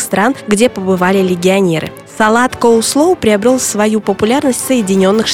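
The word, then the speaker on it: coleslaw: Он появился в Древнем Риме и носит голландское название, которое читается как «коулслоу», что в переводе с голландского означает «капустный салат».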